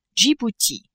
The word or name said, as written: Djibouti